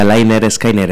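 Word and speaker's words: Lynyrd Skynyrd: Título, además, que en castellano era, se pronuncia Liner Skinner, el pronunciador Liner Skinner.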